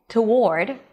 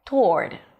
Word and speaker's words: toward: There are actually three different ways to say this word with a schwa and a W, toward, without a schwa, toward, without a W, toward. And that's how I like to pronounce it because it's the easiest pronunciation.